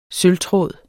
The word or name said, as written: sølvtråd